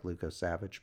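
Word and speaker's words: Lukosevicius: Which it doesn't look like it's spelled like Luko Savage, but, you know, it's pronounced that way.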